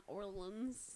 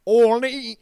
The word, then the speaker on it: Orleans: And it's not Orleans. – Orleans.